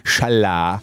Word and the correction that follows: challah: non è che mi sembra che è challah.